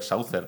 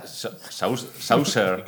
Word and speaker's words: saucer: Eh, no, es, joder, Saucer, platillo. ¿Salchicha? Saucer. – Saucer. Saucer, joder.